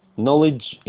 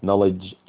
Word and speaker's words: knowledge: knowledge and knowledge الكي الأولى دي silent يعني لا تنطق